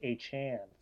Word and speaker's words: Achane: As well as other injury news, Devin Achane, been pronouncing it wrong since he was at A&M. I couldn't believe it. Went out in the media last week and said it's pronounced Achane.